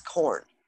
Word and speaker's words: Corne: Well, okay, so my maiden name was Corn, C O R N E, but pronounced like the vegetable.